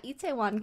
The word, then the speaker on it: Itaewon: Itaewon Class? – Pretty, you got it. Pretty close. Yeah, Itaewon Class.